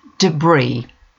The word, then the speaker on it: debris: Debris. Rubbish after an event like a storm or earthquake. Note the pronunciation, the final S is silent.